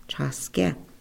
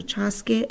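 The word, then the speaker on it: Čhaské: So let's pronounce that together before we get into our conversations. We want to acknowledge those relationships. So first of all, we want to say together, Chaske, Chaske. Chaske is for the firstborn boy.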